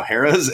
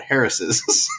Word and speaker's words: Harrah's: And she was talking about the casino Harrah's and called it Harris's.